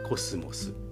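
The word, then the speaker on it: 秋桜: 漢字で書くと秋の桜と書いてコスモス。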